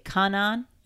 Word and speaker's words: Kanan: to say this is who Kanan was. – Or yeah, and I feel like that's. – Am I saying that right? – Yes. – Is it Kanan? – Yes.